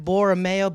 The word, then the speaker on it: Borromeo: Yeah, you can follow me at Borromeeo, BorromeeO on Instagram. – Is that the actual spelling of your last name? – No, that's phonetically on it.